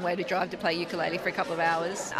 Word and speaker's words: ukulele: And that's how the Hawaiians pronounce it today, ukulele.